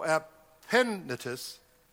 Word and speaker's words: Epaphroditus: It's actually an accent on the first part. So Epaphroditus, my beloved, and he's trying to describe here the idea behind that person as the one that was very special to him.